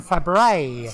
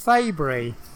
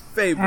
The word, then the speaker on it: Fabry: No, it's like, the strange thing is, it's like most people in America have a tendency to like sort of say, is it Glenn Fabry? And I said, no, it's supposed to be pronounced Fabry. – Fabry.